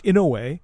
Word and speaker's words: Inoue: It's a Japanese name. It's Inoue. – Okay. – Inoue. I happen to know how to spell it or pronounce it because I knew a woman whose maiden name was Inoue.